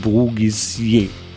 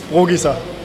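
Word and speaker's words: Bruggisser: In Mannschaftskreisen tauchte sogar die französische Variante auf, nämlich Brugisier. Wie spricht man ihn nun wirklich aus, den Namen des neuen Kapitäns der Krefeld-Pinguine? Wir haben ihn selbst gefragt und hier ist seine Antwort. – It's just Brugisa.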